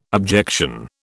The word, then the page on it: objection: British English